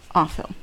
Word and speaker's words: Offill: Because I've been saying, why didn't you read that book by Jenny Offill? I've just been saying that for... – When did you read this book? I just feel bad because her name sounds like awful when you say it like that.